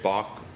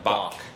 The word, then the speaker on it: Bach: 독일어 발음이죠. – 예, 왜냐면 조금 더 독일식으로 하려고 하니까. 근데 나머지 미국 사람들은 그냥 B A K처럼 Bach.